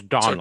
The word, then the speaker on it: Domhnall: And of course, I can tell you that the M is silent. It's Donald.